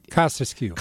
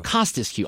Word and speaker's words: Kostescu: Jessica Kostescu is did I say her name right? – Kostescu. – Kostescu. Okay, so I have to say it like Bob Costas.